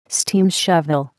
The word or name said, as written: steam shovel